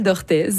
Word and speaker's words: Orthez: D'Orthez, ça finit en Orthez. EZ. Ah, il faut prononcer le Z. – Oui. – Donc, la petite ville d'Orthez.